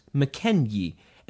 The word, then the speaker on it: Mackenzie: Mackenzie was originally pronounced McKenyi, and that Y sound was originally represented by a yog.